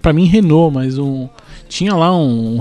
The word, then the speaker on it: Renault: E aí, assim, nesse acordo todo aqui, então a McLaren deve receber motores da Renault o ano que vem. Renault, eu falei certo? Não, a pronúncia francesa eu sei que tá certo porque eu fui numa exposição.